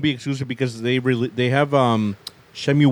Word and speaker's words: Shenmue: I know with, like, the PC gaming show, they showed a couple, like, was it Shumo 3? I can't pronounce that name. – Shenmue 3? – Shenmue 3 will be a P...